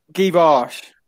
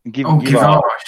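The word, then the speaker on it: Djuricic: Am I pronouncing him right? – Givash. – Givash. Oh, I know who Stefan Givash is. – I googled how to pronounce him, and apparently told me to say Givash.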